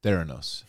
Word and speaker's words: Theranos: I think us saying, pronouncing it Thanos is going to be the most hated thing. – I'm a big Theranos guy. – The Elizabeth Holmes Company is Theranos.